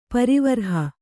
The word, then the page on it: ಪರಿವರ್ಹ: parivarha